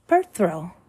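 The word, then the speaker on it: Perthro: Next one is Perthrow. Phonetic value is P, pronunciation per throw.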